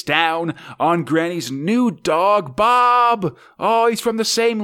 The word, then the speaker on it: Boab: Yes it's it's a uh, Bob spelled a uh, B O A B, which is how the uh, Scots pronounce Bob, basically.